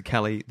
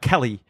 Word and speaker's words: Kelly: They mispronounce EL words. So, like Melbourne and Kelly and all that. We say it in a real, like, Kelly.